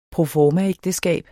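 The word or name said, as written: proformaægteskab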